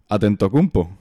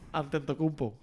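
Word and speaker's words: Antetokounmpo: Atentocumpo. – Atentocumpo.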